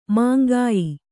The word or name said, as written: ಮೇಂಗೆಯ್